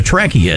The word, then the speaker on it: Trekkie: Trekkie rhyming with hippie because it came out in the 60s, sure. – Right? So that's what a Trekkie is.